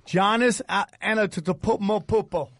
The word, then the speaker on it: Antetokounmpo: Giannis Antetokounmpo.